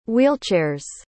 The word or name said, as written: wheelchair's